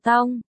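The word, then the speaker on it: tong (痛): Chú thích, 1, 2, hai chữ đều được dịch là đau, nhưng trong tiếng Hoa cách đọc khác nhau. 1, đọc là tong, 2, đọc là teng, cách chương.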